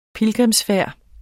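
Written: PILGRIMSFÆRDER

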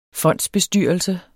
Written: fondsbestyrelse

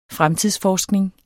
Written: fremtidsforskning

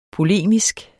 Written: POLEMISKE

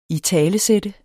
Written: italesætte

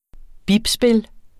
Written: bipspil

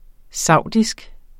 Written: saudisk

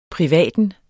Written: privaten

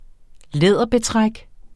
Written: læderbetræk

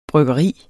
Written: bryggeri

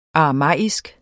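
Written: aramæisk

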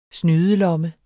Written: snydelomme

